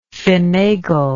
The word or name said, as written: fenagle